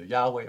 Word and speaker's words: Yahweh: That's the personal name of God, pronounced either Yahweh or Yehovah, Yehovah.